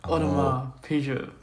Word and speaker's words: Audemars Piguet: How do you pronounce that? – Audemars Piguet. – That's the one, Audemars.